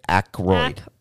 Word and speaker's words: Aykroyd: Aykroyd.